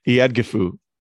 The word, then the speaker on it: Eadgifu: Is it Agatha? – No, it's not Agatha. – What is it? – Iadgifu. – Oh, it's a good thing you got it. I was like, oh, Agatha. All right, yeah. No, and you're like, pronounce this name. I was like, I could do this all day, Agatha. – It's Eadgifu.